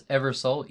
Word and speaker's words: EverSōl: That's EverSōl, EverSōl, like the sun. – Yeah. – Mm-hmm. – Pronounced soul with the long O.